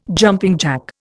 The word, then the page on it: jumping jack: Indian